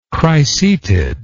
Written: cricetid

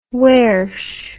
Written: wersh

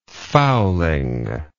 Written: fowling

